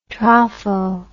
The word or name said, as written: tropho